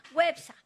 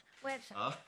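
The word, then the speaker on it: Güepsa: ¿Cómo se dice a los que nacieron en Huespa? Perdón, Huepsa. Websa, con diéresis.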